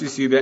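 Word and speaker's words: eusebia (εὐσέβεια): I'm sure that's absolutely not how you say it, but it's apparently pronounced eusebia, and it's the great characteristic that looks both ways.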